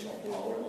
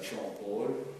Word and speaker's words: Jean Paul: Äh, der übrigens Jean Paul ausgesprochen wird und nicht äh, Jean Paul.